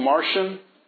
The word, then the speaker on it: Marcion: There was a heretic in, when was he, 200s, named Martian, spelled M-A-R-C-I-O-N, but it's pronounced Martian, as if somebody from Mars, M-A-R-T-I-N, Martian.